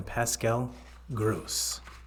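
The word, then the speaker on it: Pascal Groß: And the assist goes to none other than Pascal Gross. If I'm saying that right. – Yes, you are.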